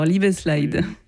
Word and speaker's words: Liebeslied: Ah, j'avais mal prononcé. Alors, Liebeslied.